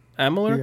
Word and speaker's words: Amalur: Well, notable releases this week is as follows: Kingdoms of I- Amalur. How do you pronounce that? Yeah. Amalur. You got it.